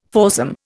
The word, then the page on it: foursome: Indian